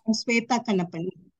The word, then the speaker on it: Suvetha Kannappan: Suvetha Kannappan.